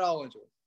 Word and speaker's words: Ollinger: Yeah, yeah, Chad Ollinger.